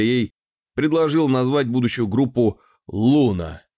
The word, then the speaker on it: Luna: Когда Вит предложил назвать группу «Луна» L-U-N-A, с ударением на первый слог, название всем понравилось.